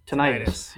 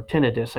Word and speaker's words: tinnitus: Yeah, or tinnitus, I guess they pronounce it. – Is that how you're supposed to say it? I've been saying it wrong my entire life. – Yeah, tinnitus is what people who seem to be medically inclined.